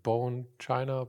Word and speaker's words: Bone China: Auf wessen Mist ist eigentlich das, ich weiß leider nicht, wie es ausgesprochen, ausgesprochen wird. Bone China, Bone. Ah, sehr gut, ja, ja, ja.